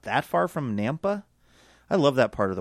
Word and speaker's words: Nampa: Ryan Driscoll of Nampa, Idaho, rhymes with Tampa.